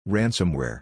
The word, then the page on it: Ransomware: English